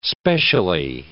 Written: specially

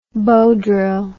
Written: bow drill

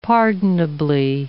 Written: pardonably

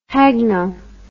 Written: hagno

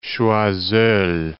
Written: Choiseul

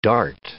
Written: dart

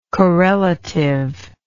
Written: correlativity

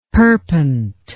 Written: perpent

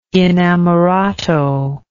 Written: inamorato

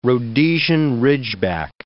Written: Rhodesian ridgeback

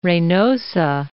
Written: Reynosa

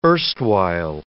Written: erstwhile

